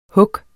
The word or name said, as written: hug